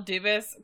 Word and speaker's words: Dubas: I did not notice until I was listening back, by the way, that you called Kyle Dubas Kyle Dubois last... – You know, all these fucking Canadians with French spelling names that don't actually pronounce them in the French way, and my mind immediately goes to the French pronunciation.